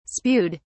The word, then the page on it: spewed: British English